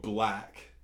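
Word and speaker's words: 6LACK: And then there's a rapper called Black, but it's 6lakh.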